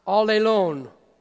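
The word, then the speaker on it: allelon (ἀλλήλων): It's ironic because that one word in the Greek is pronounced allelon.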